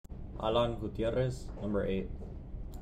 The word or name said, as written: Alan Gutierrez